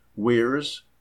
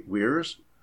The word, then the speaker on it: Weires: Weirs, I'll spell that, W-E-I-R-E-S, Isaac J. Weirs, Madison, Wisconsin.